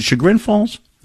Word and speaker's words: Chagrin Falls: Is it Chagrin Falls? – Yes, you got it.